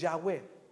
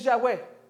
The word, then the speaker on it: Yahweh: Si, si la pronunciamos correctamente, no mueves ni la boca ni la lengua. Si la pronunciamos correctamente como fue inspirada, como fue enseñada. Ya, Si la pronunciamos correctamente. La palabra Yahweh, ya lo expliqué un poquito, dice, eh, las letras fue un intento de imitar, mire, la respiración, de, perdón, de imitar y eh, replicar la respiración.